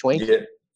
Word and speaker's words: Schwenke: Am I pronouncing that correctly? It's Schwenke. – Yeah, sounds like.